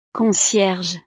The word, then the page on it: concierge: frances